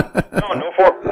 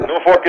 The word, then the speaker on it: Norfolk: No, Norfolk. Norfolk is how you say it.